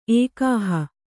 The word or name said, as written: ಏಕಾಹ